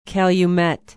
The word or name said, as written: Calumet